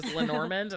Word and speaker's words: Lenormand: And they came to bear the name Lenormand, I'm assuming because she's French, that it's named after this woman, Marie-Anne Lenormand, which is how I pronounced that word until I heard somebody use it on an Instagram story as Lenormand. And I was like, oh, okay. – Okay. – That works too.